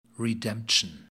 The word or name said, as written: redemption